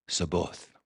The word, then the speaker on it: Sabaoth: The Lord of hosts, the God of the angels, Sabaoth, is how you say it in Hebrew.